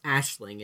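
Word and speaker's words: Aisling: And it's because he too, like, having heard it from the police, is convinced that Philo has killed, it's pronounced Ashling, it's spelled with an A-I, but the singer, his mother, Philo's mother.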